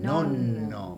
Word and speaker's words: nonno: Nosotros tal vez lo decíamos con una N sola, pero en italiano vero, puro vero, es doppia N, en el final. Sería nonna, sí. Nonno, porque las doppias se pronuncian.